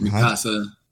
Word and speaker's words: Mikasa: I love Misaka and Hanji. Is that how you pronounce his name? I can't remember. – Mikasa and Hanji.